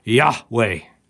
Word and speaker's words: Yahweh: Nobody knows how to pronounce it, but the best we can do, we think it's pronounced Yahweh.